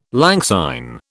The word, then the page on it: lang syne: British English